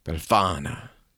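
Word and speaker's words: Befana: She's called Belafana, if I'm saying that right, but she's an old woman. – Spell it. – B-E-L-F-A-N-A. – Belafana. – Yeah, that's better.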